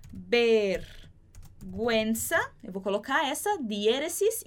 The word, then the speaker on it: vergüenza: E se eu quiser fazer esse som de gui, eu vou colocar também a diéresis em cima desse U, por exemplo, como na palavra pinguino. Então, vergüenza é vergonha e pinguino é pinguim.